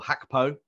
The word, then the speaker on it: Gakpo: I actually think that Gakpo, or Hakpo, you're right, that is the Dutch pronunciation.